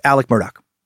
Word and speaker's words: Alex Murdaugh: Following that suck, we traveled to South Carolina's Low Country, examined the terrible crimes of Alex Murdaugh, but pronounced Alec Murdaugh.